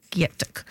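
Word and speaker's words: Gyetk: And you can follow Rachel Greening on Instagram to stay up to date, and you'll see beautiful images from the cookbook, which is spelt G-Y-E-T-K, G-Y-E-T-K, Gyatuk, Feed the People.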